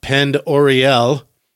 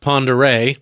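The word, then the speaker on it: Pend Oreille: Now, you may know that as the home of Lake, looks like it's spelled Pend Oriel, pronounced Pondere.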